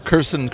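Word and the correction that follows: Cuarón: Curson, Alfonso. Cuaron.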